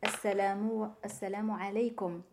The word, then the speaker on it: assalamu alaikum: Normalement, c'est assalamu alaikum. Assalamu alaikum. Parce qu'on prononce le A, ça n'existe pas en français.